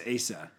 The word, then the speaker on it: Asa: It's pronounced Asa. And the only reason I know that, Matt, is because I know people named Asa okay. – after this king.